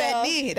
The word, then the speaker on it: pernil: It's so, pernil. That's how you say it.